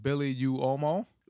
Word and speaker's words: Billy Uomo: Is it Billy Uomo? – It's like Uomo. – Uomo in Italian is like whoa, you know, like a little, like just a little W, tiny one.